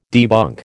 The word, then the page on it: debunk: American English